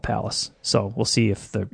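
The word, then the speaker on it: Pallas: Palace. Spelled like the city of Dallas, except with a P. That's P-A-L-L-A-S.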